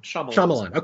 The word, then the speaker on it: Shyamalan: And am I pronouncing his name correctly? Is that Shyamalan? – Shyamalan. – Okay.